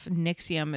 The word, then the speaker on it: NXIVM: He's the founder of Nixium. It's spelled N-X-I-V-M.